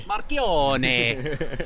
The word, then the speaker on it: Marchionne: I did read that article today, and I think Sergio Marchionne or something like that. – Yep. – Maybe Marchionne. – That'll do. – Oh, we're all pronouncing in Italian now, are we?